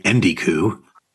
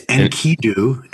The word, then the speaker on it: Enkidu: If you say Enkidu, it's Enkidu. Enkidu. – Enkidu. – You're flipping the consonants.